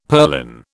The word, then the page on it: purlin: British English